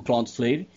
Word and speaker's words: Cloudflare: Porque a palavra é Cloud, que é de nuvem, e Flare, F-L-A-R-E. Então, Flare. Cloudflare. Deve ser sim a pronúncia correta.